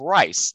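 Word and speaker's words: Rhys: And if you're really daring, he is Price Rust, because Price, which is spelled P-R-Y-C-E, is a patronymic name derived from the Welsh meaning son of rice. That's R-H-Y-S.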